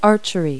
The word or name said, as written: archery